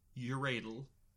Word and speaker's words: Yuradle: Uh, y U R A D L E, uradal. – Okay. – It is not Uradle. I don't know why there's an L in it.